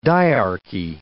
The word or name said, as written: dyarchy